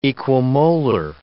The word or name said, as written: equimolar